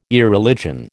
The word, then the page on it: Irreligion: American English